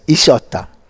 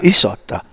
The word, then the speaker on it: Isotta: Or actually, in Italian, it's Isotta. – Yeah. – Isotta. So they pronounce it like it's an E, but it's an I. It's spelled with an I.